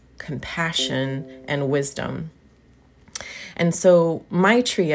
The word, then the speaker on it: maitri: So it sounds like my tree, but it's spelled M-A-I-T-R-I.